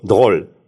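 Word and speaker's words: drôle: Она по-креольски, она же из Мартиники, а там такой акцент, там букву «Р» почти не произносится. Есть такое французское слово «дроль». «Дроль» – это французское слово, значит, забавный. И она говорила «Бонапарт», она «Р» не произносит, «Бонапарт» или «Доль», «Доль», «Доль», он забавный.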